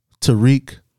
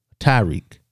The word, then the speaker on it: Tariq: When people call Tariq Tyreek, mm-hmm. – I hate that. That's not how it's spelled, y'all.